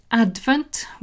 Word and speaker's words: advent: Pronunciation is advent with the stress on the first syllable.